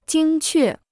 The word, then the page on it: 精确: jīng què